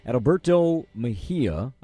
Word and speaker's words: Adalberto Mejia: And correct me if I'm mispronouncing his name, Adalberto Mejia, a left-handed starter. Am I saying his name right? – Yeah, that's pretty good.